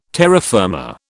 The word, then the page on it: terra firma: British English